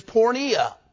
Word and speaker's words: porneia: And, of course, the word fornication, the Greek word of it, is porneia. Porneia. And it's spelled P-O-R-N-E-I-A. And that is where we get our English word, pornography.